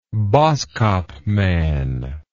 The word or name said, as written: boskopman